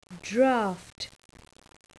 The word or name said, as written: draught